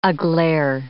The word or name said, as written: aglare